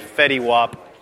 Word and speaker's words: Fetty Wap: I got to get Fetty Wap.